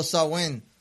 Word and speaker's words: Samhain: Este cambio anual de la estación y estilo de vida estuvo marcado por un festival llamado Samhain, pronunciado Samhain, cerda rima con vaca, que significa fin del verano.